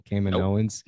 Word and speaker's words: Kaminoans: Am I still saying that wrong? Kaminoans? – Nope.